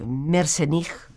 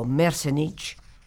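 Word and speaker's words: Merzenich: El doctor Mersenich o Mersenich, como tú quieras pronunciarlo, es probablemente el gran pionero en este esfuerzo por demostrar científicamente cómo el cerebro no tiene nada de estático.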